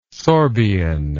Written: sorbian

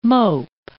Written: mope

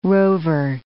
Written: rover

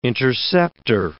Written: interceptor

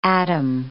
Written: Adam

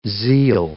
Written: zeal

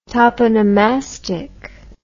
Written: toponomastic

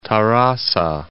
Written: Tarrasa

